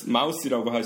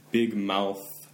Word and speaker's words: mouth: big mouth, mouse라고 하시면은 큰 귀 기운다 이렇게 되부를 수도 있잖아요. – 가끔 잘 안 돼요. – 어렵죠. 예. 그래서 마지막에 그 발음 이렇게 마무리할 때 혀 예. 이렇게 이 밖으로 바깥쪽으로 이렇게 내밀고 있어야죠. big mouth, big mouth.